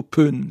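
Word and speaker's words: Bön: À la Saint, on prononce plutôt peun, n'est-ce pas? – Oui.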